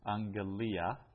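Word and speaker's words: angelia (ἀγγελία): and then using the pattern haute esten he angelia. This is the message. Remember that double gamma there is pronounced as ng.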